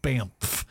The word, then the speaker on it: Banff: In Canada, they have like Banff. How do you say that? – Just like that.